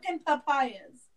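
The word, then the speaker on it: Tolkepaya: Tolkapayas. – Yeah, like Tolkien Pious. Tolkapayas.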